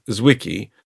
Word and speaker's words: Zwicky: In 1933, the Swiss astronomer Fritz Zwicky, that's Z-W-I-C-K-Y, Americans would say Z-W-I-C-K-Y,